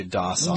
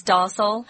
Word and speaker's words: docile: Maybe some Americans will say docile. – No, I've never heard, unless they're mispronouncing it. In America, it is docile, but if you go to England, it's docile.